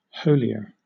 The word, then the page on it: holier: British English